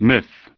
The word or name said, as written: Myth